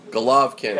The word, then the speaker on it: Golovkin: Triple G or G-O-L-O-V-K-I-N. Golovkin.